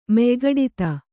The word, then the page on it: ಮೆಯ್ಗಡಿತ: Kannada